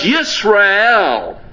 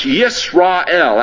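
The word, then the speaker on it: Yisrael (ישראל): See, because Yisrael, you've got on the back of that word, it's properly pronounced Yisrael. That's it. Begins, the word Israel begins with a yud in the Hebrew, a yud, a Y sound. It's Yisrael.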